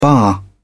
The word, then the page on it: baa: British English